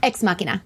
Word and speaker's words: Ex Machina: And I feel the beginning of this episode, it's, how do you pronounce it? El Machina? – Ex Machina.